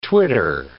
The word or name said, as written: twitter